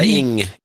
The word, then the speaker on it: Ng: Yeah, Ing.